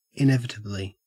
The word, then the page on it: inevitably: Australian English